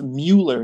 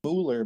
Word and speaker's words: Muller: I thought it was pronounced like Muller Light Beer, but it's not. It's Muller. – No, I guess in German, Muller, but I guess the American pronunciation is Muller.